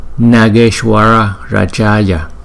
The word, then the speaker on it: Nageshwara Rajaya: Mình phát âm là Nageshwara Rajaya.